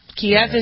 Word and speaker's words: Kyiv: Kiev is the Russian pronunciation. and Kyiv is.